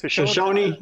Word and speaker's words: Shoshone: Shoshone.